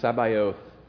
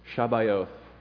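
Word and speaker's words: Sabaoth (צְבָאוֹת): That word, Lord of hosts, in Hebrew, it's the hosts is of armies, and it's pronounced Sabaoth, Shabaoth.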